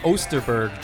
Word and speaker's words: Osterberg: Can you pronounce his last name again? – Ostenberg? – I'll give it to you. James Osterberg Jr. Thank you.